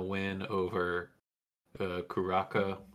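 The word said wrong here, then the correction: Curaçao: I didn't pronounce that right. Uh, small uh, oh, Curacao.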